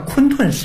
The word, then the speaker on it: 混沌: 盘古开天地，盘古的姓氏叫盘古氏，又叫做混沌氏，但是那个字的古读音呢叫昆沌氏，